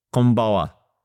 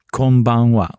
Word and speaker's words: konbanwa: So can you break it down the... – K-O-N. Kon. – Kon. – Ban. B-A-N. Wa. – Oh, konbanwa. – Yes, perfect. Konbanwa.